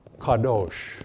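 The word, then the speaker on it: kadosh (קדוש): One is the more prolific general word, and this is, I'm putting up here the English pronunciation of the word kadosh.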